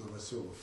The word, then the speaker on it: Novoselov: And for the interest it might have, we speak Russian can tell you that one of the laureates is written as Novoselov, but he's Novoselov, pronounced Novoselov in Russian.